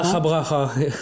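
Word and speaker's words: DakhaBrakha: Nous écoutons Dakabra. Braha. Est-ce que je prononce bien? Non, mal certainement. – Dacha Braha. – Voilà, vous n'êtes pas loin. – Je suis pas loin. Alors, Dacha Braha.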